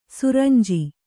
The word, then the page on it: ಸುರಂಜಿ: suranji